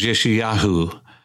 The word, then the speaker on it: Yeshayahu: Isaiah is Yeshayahu. That's quite a bit of difference, but Micah is Micah.